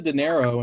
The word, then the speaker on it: dinero: Give me the dinero and hold the streamers. – Give me the dinero. – Yeah. Did I say that correctly? Dinero? – Yeah. All good. All good.